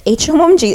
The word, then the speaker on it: Hmong: it's H O M G H H is silent